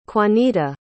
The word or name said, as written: quanita